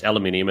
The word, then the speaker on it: aluminium: So copper involved in everything, aluminium, alumina, or however you say it, the same thing. – Aluminium, also, that's how you say it. How is it? There's no other way of saying it.